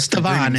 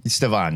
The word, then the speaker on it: Stevan: Stevan is how I'm pronouncing that first name. – Stevan, you're probably right.